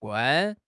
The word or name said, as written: 罐